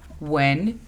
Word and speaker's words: when: When significa cuando. La pronunciación fonética es U, E, N. When.